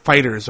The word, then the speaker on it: FighterZ: Which is supposed to be pronounced FighterZ, apparently. Bandai Namco put out a tweet. Official press release. That's what a press release is these days. We just tweet it out. We'll put it on Facebook, right? And it said it's pronounced FighterZ. Not FighterZ or FighterZ.